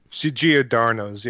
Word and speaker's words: Giordano's: Was it like Giordano's? – Giordano's, yeah.